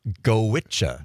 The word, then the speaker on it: Goitse: It's called Gowicha. – Gowicha. – Gowicha. – It's Irish. – Common pronunciation. – Yeah, I know. I know. – Gowicha. How do you get G-O-I-T-S-E, Gowicha? – You'll have to ask the Irish.